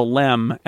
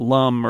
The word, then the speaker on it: LM: but it was Lunar Excursion Module, LEM, was very obviously called the LEM. And that's why, even though it's the LM, everybody pronounced it LEM, not LUM or LIM or whatever. It was always the LEM.